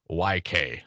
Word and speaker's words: Tomczyk: You can find out about each and every one of these, like Republican Senator Corey Tomczyk of Mozyn. I think I'm pronouncing that right. Tomczyk. – I believe so. – Tomczyk.